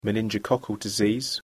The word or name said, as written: meningococcal disease